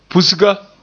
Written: pusga

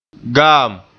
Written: gaam

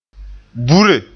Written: bure

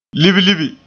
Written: libilibi

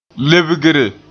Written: lebgre

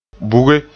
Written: buge

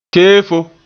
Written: keefo

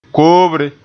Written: koobre

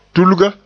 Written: tulga

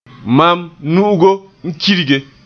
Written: kirge

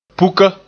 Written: puka

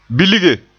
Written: bilge

